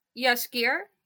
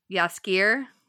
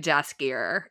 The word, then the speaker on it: Jaskier: And then the bard who on the show is called, well, how do you say his name on the show? – Yaskier. – Yaskier. Jeez. See, I've only seen it written, so in my head I was like a Jaskier.